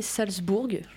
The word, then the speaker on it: Salzbourg: Euh, le Bayern s'est imposé largement contre le RB Salzbourg.